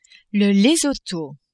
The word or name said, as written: Lesotho